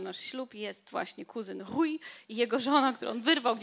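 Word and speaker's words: Rui: Pisze się R, U, I. E, I e, litery R, które są na początku wyrazu albo które są podwójne, jak w słowie gitara, wymawia się jak takie ch. No więc mówimy rój. Nawet czasami jeszcze bardziej na H.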